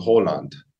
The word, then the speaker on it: Haaland: So his name is Erling Haaland and this sort of AA pronunciation in in Norwegian is really unique because it's somewhere in between an A and an O,